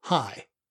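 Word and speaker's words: Hy: John, I have an uncle, High, and it's spelled the same way, H-Y. It is short for Henry, but I guess they shorten it to High.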